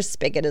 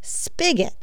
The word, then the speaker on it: spigot: Spigot is the correct answer. S-P-I-G-O-T. Did you get that right? Spigot, S-P-I-C-K-E-T, though commonly said and written, is not actually a word. Please stop. This confusion dates back to the Middle English word spigot, S-P-Y-K-E-T, so I wouldn't feel too bad about it if you've had this wrong for years and years.